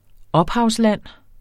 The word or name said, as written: ophavsland